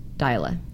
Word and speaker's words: Dyla: Okay, here's a question from Dyla. Yeah, she said it looks like Dayla, but it's pronounced Dyla.